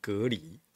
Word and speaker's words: 蛤蜊: 对啊，我我知道有人说哦，这个现在蛤蜊要念成隔离啊，哦，好像是用那个。